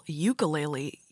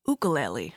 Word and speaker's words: ukulele: He also taught me this morning, or uh, his native friend from Hawaii taught me that it's ukulele because he said it's uh, what what I used to call ukulele. You pronounce it like clue, so ukulele.